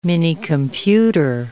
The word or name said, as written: minicomputer